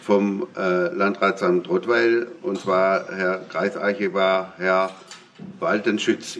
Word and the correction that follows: Waldschütz: ich hoffe, dass ich es richtig ausgesprochen habe, oder Waldschütz, ja.